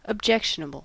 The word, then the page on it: objectionable: American English